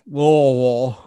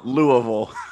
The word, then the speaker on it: Louisville: So I'll say it like this, like I'm like swallowing a golf ball. Louisville. – Louisville.